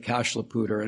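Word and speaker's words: Cache la Poudre: the Cashlapooter, and we don't say that with the French pronunciation, so we say it incorrectly, but it's correct for Colorado standards.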